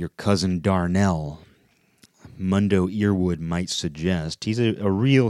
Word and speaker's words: Mundo Earwood: And Mundo is spelled M-U-N-D-O, last name E-A-R-W-O-O-D. Mundo Earwood. What do you say about that? You say Mundo Earwood.